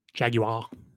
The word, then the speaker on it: Jaguar: You said it wrong again. Jaguar.